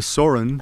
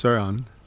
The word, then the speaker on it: Søren: Well, my name being Soren, in Danish it's Søren.